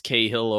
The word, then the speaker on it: Cahill: it's pronounced Cahill over here. But, you know, it's just kind of like saying Carl without the hard R.